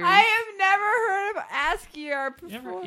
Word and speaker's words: ASCII: Like ASCII art.